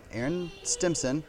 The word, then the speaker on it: Aaron Stimpson: But with me right now, I actually have Aaron Stimpson.